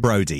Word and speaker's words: Brodie: Brodie, yes, right, okay.